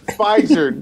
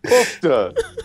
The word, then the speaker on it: Pfizer: Pfizer. Pfizer, not Pufta.